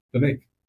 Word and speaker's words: Vivek: Vivek. Comes with cake.